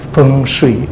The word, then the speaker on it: feng shui: So, but actually, the proper Beijing dialect is adopted as a universal Mandarin pronunciation. So it's feng, so that's why it's feng shui.